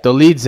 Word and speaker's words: Dolidze: Joaquin Buckley, RoboCop, Adrian Yanez, Jeremiah Wells, Ricardo Hamos, Cody Stamen, Phil Hawes, and our boy Roman Dolidze, I believe is how you say his name. – Dolidze, there you go.